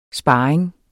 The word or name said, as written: sparring